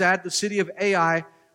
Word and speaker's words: Ai: Ai. That's how it's pronounced, the two letters, Ai.